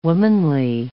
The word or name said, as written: womanly